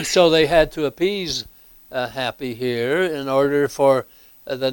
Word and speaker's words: Hapi: And the Nile deity here was, I think it's pronounced Happy here, H-A-P-I.